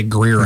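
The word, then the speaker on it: Greear: It's G-R-E-E-A-R. Greer? – I'd just say Greer.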